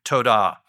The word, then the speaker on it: todah: Well, the general translation is a Hebrew word that means that's pronounced todah.